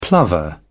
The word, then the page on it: plover: British English